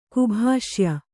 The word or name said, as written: ಕುಭಾಷ್ಯ